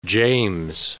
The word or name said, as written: James